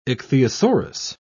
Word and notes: ichthyosaurus: British English

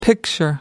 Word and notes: picture: American English

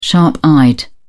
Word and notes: sharp-eyed: British English

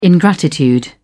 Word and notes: ingratitude: British English

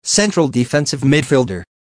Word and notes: central defensive midfielder: American English

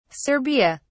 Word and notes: serbia: British English

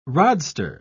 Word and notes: rodster: British English

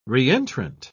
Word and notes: re-entrant: British English

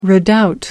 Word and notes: redoubt: American English

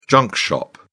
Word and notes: junk shop: British English

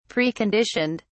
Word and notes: preconditioned: British English

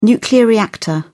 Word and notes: nuclear reactor: British English

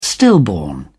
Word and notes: stillborn: British English

